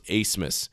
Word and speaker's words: Abmas: Oral Roberts has Max Asmus, and that's spelled A-B-M-A-S, but it's pronounced Asmus.